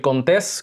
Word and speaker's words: Contés: mi nombre es Miguel Contés, con acento en la E, y nos vemos la próxima.